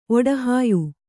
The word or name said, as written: ಒಡಹಾಯ್